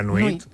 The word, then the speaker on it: nuit: Nuit. La nuit.